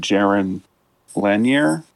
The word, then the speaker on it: Jaron Lanier: Jaron Lanier? Mm-hmm.